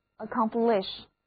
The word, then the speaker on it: accomplish: 注意字母 A 要读作短的 a，而字母 O 还是读 O，第二个音节是重读音节 accomplish。